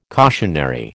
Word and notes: cautionary: American English

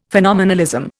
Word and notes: phenomenalism: Indian

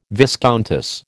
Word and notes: viscountess: American English

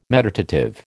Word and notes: meditative: American English

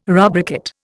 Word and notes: rubricate: Indian

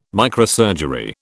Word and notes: microsurgery: British English

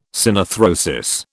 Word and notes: synarthrosis: British English

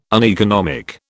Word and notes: uneconomic: British English

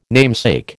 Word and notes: namesake: American English

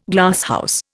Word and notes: Glasshouse: Indian

